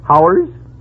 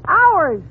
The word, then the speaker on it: hours: Now, how do you pronounce H-O-R-S-E? – H-O-R? Oh, that's easy. That's horse. – Fine. Now, how do you pronounce H-O-U-R-S? – H-O-U-R-S? Howers? – You pronounce it ours.